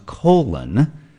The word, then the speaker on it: Cologne: No, it apparently is pronounced colon, like we all have a colon.